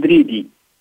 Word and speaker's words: Dridi: Amine Dridi.